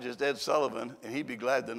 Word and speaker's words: Ed Sullivan: Ed Sullivan, is that? Yeah, that's how you say it.